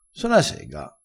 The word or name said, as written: so na sega